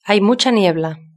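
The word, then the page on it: hay mucha niebla: Spain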